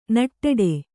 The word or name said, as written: ನಿಟ್ಟಡಿ